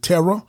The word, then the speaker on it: Terah: Now, some people pronounce Tira is Abraham's father, which you'll see in Genesis chapter 11. Uh, but some people pronounce it Terra, but I pronounce it Tira, and that's how I'm going to do with this episode.